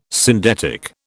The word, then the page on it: syndetic: British English